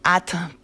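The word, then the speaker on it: @: Se llaman At, pero bueno, esa es la forma en la que se pronuncia, porque en realidad su nombre se escribe directamente con una arroba.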